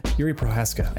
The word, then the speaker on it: Jiří Procházka: Jiří Procházka.